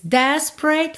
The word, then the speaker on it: desperate: Perceba como temos duas letras E que não são pronunciadas, tá? Não é desperate, tá? Desperate, pre, pre. Pensa em spray, ok? Que do P vai direto pro R.